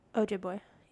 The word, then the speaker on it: Ojibwe: How do you say the Native American tribe that she's from? – I thought I wrote it down, but I'm not seeing it. – Ojibwe? – Yeah. – Ojibwe, yeah.